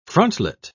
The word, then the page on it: frontlet: British English